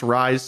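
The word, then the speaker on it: Reye's: It's pronounced Rise syndrome. The one I found is just R E Y E apostrophe S, Rise syndrome. According to first YouTube video that says the pronunciation, they pronounce it Rise syndrome.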